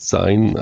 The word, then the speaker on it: ZINE: I had to check that, what we did last time, because there was a discussion from the beginning, because it comes from the word magazine, but scene is like not, like if you just see that those four letters, you would pronounce it sign. And that's basically when we made the decision to stick to the sign term.